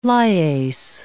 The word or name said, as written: lyase